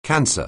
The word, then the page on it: cancer: anglais